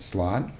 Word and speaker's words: slaad: His assistant is, if anyone is familiar with a slod, I believe I'm saying that right, slod. S-L-A-A-D.